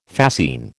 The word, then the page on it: fascine: American English